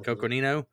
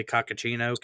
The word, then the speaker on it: Coconino: It's like Coconino County. Mm-hmm. Coconino. I kept wanting to say Cocachino County.